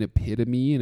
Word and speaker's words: epitome: It said epitome, and she's like, you mean epitome?